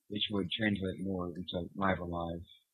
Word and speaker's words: Live A Live: I think Live a Live is the correct pronunciation, though.